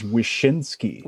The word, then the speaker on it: Wyszynski: That's like the most common mispronunciation that I hear of the last name. – So it's Wozinski. School me. – Yeah, please. – It is Wyszynski.